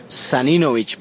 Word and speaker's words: Saninovich: ¿Cómo se pronuncia? – Saninovich, pero la verdad, como lo pronuncia cada cual, eso, cada persona que conoció la pronunció de una forma diferente y lo importante es que todo el mundo sabe de quién se trata.